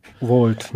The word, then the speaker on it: Vault: Wie spricht man das aus? Vault. Vault, ne? Ja.